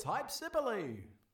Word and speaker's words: Hypsipyle: It's Hypsipyle.